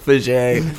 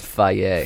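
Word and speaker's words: Fage: Fuck Fajay. – You guys should do a fake sponsorship every single time until it comes true. – Oh, that's good. – You know? – It's actually pronounced Faye.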